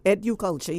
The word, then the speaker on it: EduCulture: EduCulture, yes.